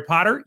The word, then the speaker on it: Potter: My name is Jerry Potter. Yes, it rhymes with the boy wizard,